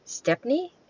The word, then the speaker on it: Stepney: Steffi, no, no, I said that wrong. Stepney, S-T-E-P-N-E-Y.